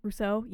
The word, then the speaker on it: Rousseau: Rousseau? – Rousseau, yeah.